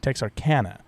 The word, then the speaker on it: Texarkana: Is about the Moonlight Murders, which took place in the 1940s in, I always forget how to pronounce it, is it Texarkana? – Texarkana.